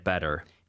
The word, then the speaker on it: Better: I'll go tomorrow if I don't get better. – 빈칸에는? – Better, B-E-T-T-E-R. – 네, 하지만 발음은 T 발음 안 하고 북미권에서는 Better. 이렇게 부드럽게 T를 발음한다고 했었죠.